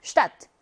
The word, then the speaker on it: Stadt: 이렇게 SCH 조합이거나 ST 조합일 때 S, SP 조합일 때 S가 SH 발음을 내요. 한번 읽어볼까요? Schule, Stadt.